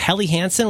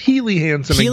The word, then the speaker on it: Helly Hansen: Now, when I met my wife, she had, was it Helly Hansen? Was that the name of it? – Yeah, it's pronounced Healy Hansen against all logic.